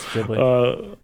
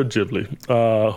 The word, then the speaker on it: Ghibli: Along with Studio Ghibli. – Ghibli. – Damn it. – That's Ghibli. – Along with Studio Ghibli, who obviously, of course, made Spirited Away and Princess Mononoke.